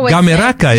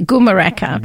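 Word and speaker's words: Gumeracha: I tend to say Gumeraka. Gumeraka. They tell me that's not quite right.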